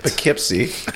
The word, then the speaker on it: Poughkeepsie: Poughkeepsie. – Right. – Did I say it right? – Yeah.